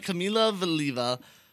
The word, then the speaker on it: Kamila Valieva: Russian figure skater, 15-year-old Kamila Valieva. I believe that's how you pronounce it. – That Valieva, sounded right. – Kamila Valieva.